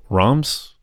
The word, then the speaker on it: Reims: So Reims, I guess, is how I'm supposed to pronounce it.